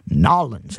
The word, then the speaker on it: Nawlins: Those of you that know New Orleans, Nawlins, that's how it's pronounced.